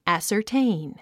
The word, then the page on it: ascertain: æ`sə r téin; アッサァテイン